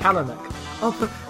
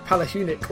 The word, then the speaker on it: Palahniuk: Palahniuk. I'm putting an emphasis on the H when the H isn't meant to have any emphasis whatsoever. I've been saying like Palahniuk all these years, and I apologise.